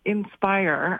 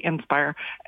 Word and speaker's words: Indspire: Inspire, which is spelled INDSPIRE, Inspire, is an Indigenous-led organization that raises money for scholarships for Indigenous people in Canada.